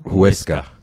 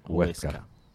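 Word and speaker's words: Huesca: هويسكا شكرا هويسكا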